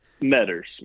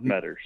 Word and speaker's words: Metters: Is that correct? Is that how you say your last name? – Metters. Mark Metters. – Metters. Okay, good.